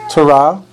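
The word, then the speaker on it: Teruah: Like I said, today it's known as Rosh Hashanah, also known as Yom Terah, known as Trumpets.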